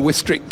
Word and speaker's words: Wistrick: He produced his best results for the American volleyball player Alessio Wistrick. I'll never forget Keith Urban Wistrick Council. – He had to look that up. – Rhymes with district.